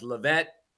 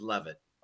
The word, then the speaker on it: Leavitt: The original pronunciation was Levitt but over the years, the English translation is love it.